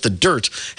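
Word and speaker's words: The Dyrt: A popular camping resource, the Dirt, D-Y-R-T, pronounced the Dirt, has released its 2023 camping report, this year's annual dive into various aspects of camping around the United States.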